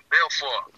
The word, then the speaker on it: Balfour: By the Balfour. Balfour. – Yeah, Balfour. – You know, I forgot. You know what I'm saying. – Yeah. I said it wrong.